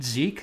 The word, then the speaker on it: Zeke: And I, Jay Zilski, am at Seed Zeke. So Zeke is spelled Z-E-K-E.